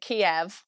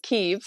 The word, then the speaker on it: Kyiv: And I realized as well that actually only recently I've been hearing, I used to pronounce it Kiev, but I think people in Ukraine pronounce it Kyiv. So I realized I've probably been pronouncing it wrong my whole life.